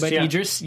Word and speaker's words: Idris: Idris, I'm sorry, I can't say his name right. – Idris, yes.